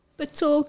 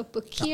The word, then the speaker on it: PETOR: Kan iemand van jullie dat gauw pronounce? – PETOR, creative. – Ik moet wel zeggen, ik is verkeerd, man. – Just think French. Yes. – It's a combination of our surnames. Mm-hmm. So PETOR.